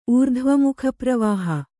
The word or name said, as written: ಊರ್ಧ್ವಮುಖಪ್ರವಾಹ